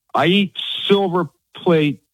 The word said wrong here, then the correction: Silver Palate: Silver palate pasta sauce.